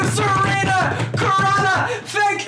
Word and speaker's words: Surina Khurana: Surina Khurana. – Did I say it right? – Yeah.